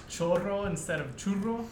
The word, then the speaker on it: churro: That'll be up too, referencing the churros. Heron, give you a little education on that one too. – Say it right. – Hey, churro. – There you go.